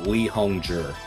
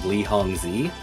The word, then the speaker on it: Li Hongzhi: You'll see a lot of people pronounce his name Lee Zi, but that is because most people who are saying his name don't have a background in reading pinyin.